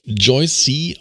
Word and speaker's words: Joyce: Sie nennt sich, also sie wird Joyce geschrieben, aber Joyce C. ausgesprochen, habe ich mir sagen lassen, in Brasilien.